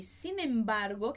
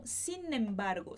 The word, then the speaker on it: sin embargo: Para você entender um pouquinho melhor esse sin embargo que a gente tem no espanhol, e a pronúncia a gente vai acabar juntando o N com o E. Então, sin embargo.